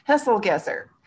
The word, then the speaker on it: Hesselgesser: Hesselgesser.